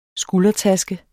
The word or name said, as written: skuldertaske